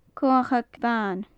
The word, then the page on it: comhachag bhan: gaelic